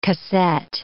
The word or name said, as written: casette